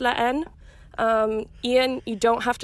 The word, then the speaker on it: La Haine: You just pretend the H is there. So it's la Haine.